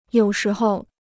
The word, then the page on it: 有时候: yǒu shí hou